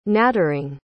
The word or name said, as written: nattering